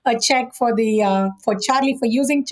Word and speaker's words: Charlee: And we'll look forward to hearing more about Charlee as time goes on. – Well, it definitely rhymes with Lee.